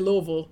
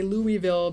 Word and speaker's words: Louisville: in Louisville, we say Louisville. But outside of there, we have to say Louisville because then people don't understand what you're saying.